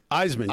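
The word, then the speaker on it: Eisman: Iceman, yeah. Iceman. Like the Heisman Trophy, no H.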